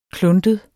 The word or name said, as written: kluntet